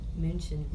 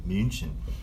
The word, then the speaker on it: München: How do you say it in German? – München. – München.